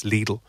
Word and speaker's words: Lidl: They literally ring the station two seconds later going, sorry, you're not pronouncing that correctly. It's Lidl. – You, oh. you have to oh. say it like Lidl.